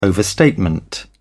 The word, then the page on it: overstatement: British English